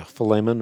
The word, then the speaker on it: Philemon: And I'm in the book of, pronouncing it correctly, Philemon? – Yeah, Philemon, or, yes.